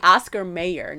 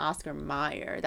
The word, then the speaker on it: Oscar Mayer: See, Oscar Mayer, not Oscar Meyer. That's why you spell it wrong. So one of the most confounding of all is there's an A in Oscar Meyer. Honest to goodness, we thought it was Meyer. Mayer.